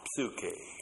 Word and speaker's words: psuche: The intensive pronoun autos uses the third person singular personal pronoun plus the noun psuche. P-S-U-C-H, long E.